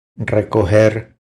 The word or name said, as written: Recoger